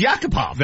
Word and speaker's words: Yakupov: I mean, that's his proper pronunciation. It's Yakupov.